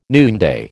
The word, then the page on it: noonday: American English